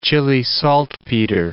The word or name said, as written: Chile saltpeter